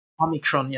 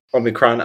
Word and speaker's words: Omicron: And well, so the, the South um, Africa discovered the uh, the uh, the Omi- uh, how do you pronounce it? Omicron, right? Omicron, yeah. – Omicron, uh, they, they, they and so some people were so then there was a uh, shutdowns.